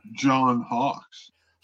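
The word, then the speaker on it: John Hawkes: And John Hayes, who plays the other police officer in there, who's kind of against her. Am I saying his name right? – John Hawks.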